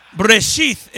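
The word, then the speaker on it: Breshith (בראשית): But it's Breshith is how you say it.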